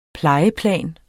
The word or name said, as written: plejeplan